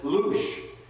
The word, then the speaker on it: Blush: Another couple that I got to meet that I want to mention, his name is Lush. It's spelled Blush, but he likes to be called Lush. He and his wife Maria are from Romania, but they live in Vienna.